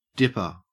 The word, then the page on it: dipper: Australian English